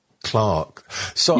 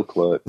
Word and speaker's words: Clerks: I know it's Clerks, because that's the way the English pronounce it, but it's an American film, so I normally say Clarks. – Yeah, they're a shoemaker. No, it's still Clerks.